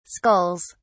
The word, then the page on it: skulls: British English